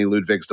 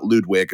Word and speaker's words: Ludwig: I think there was many Ludwigs to follow, and I love that name, so I like saying it correctly. It's not Ludwig, it's Ludwig.